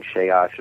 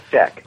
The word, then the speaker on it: Schick: I kept saying Sheosh and things like that. They said, no, it's Schick.